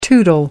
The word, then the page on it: tootle: American English